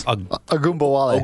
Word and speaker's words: Ogunbowale: Or Dare Ogunbowale. Did I say that right? – Pretty close. Ogunbowale. – Ogunbowale. Ogunbowale.